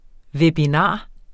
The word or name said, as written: webinar